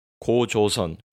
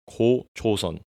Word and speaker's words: 고조선: Also known by the English name National Foundation Day, this holiday celebrates the legendary formation of the first Korean state of Gojoseon in 2333 BC. That's right, that's 2333 BC, motherfucker. Gojoseon, my Korean pronunciation a bit off. 고조선, 고조선.